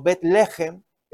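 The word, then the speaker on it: Betlehem (בית לחם): Efectivamente, Belén o Betlehem, es la pronunciación en hebreo, significa Bet es casa.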